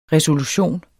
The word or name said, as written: RESOLUTIONER